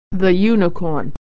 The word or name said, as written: unicorn